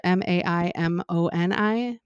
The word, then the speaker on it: Maimoni: His name was Thomas um, I think it's Maimoni is how you say it. M A I M O N I, Maimoni.